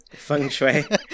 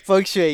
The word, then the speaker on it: feng shui: Feng Shui. – Feng Shui, yes.